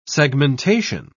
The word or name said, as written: segmentation